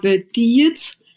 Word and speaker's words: Diget: And then you have Diget, Sletterød. So again, it's the sound you need to make, like you're throwing up or something. That's the Danish way to pronounce things.